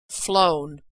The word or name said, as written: flown